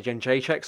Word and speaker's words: Jędrzejczyk: In the in the post fight, for me, a great call out would be Joanna uh, Jedrzejczyk, Joanna Jędrzejczyk, sorry, get the name right.